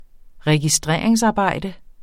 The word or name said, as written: registreringsarbejde